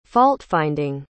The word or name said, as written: fault-finding